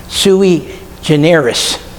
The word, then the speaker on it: sui generis: Sui generis.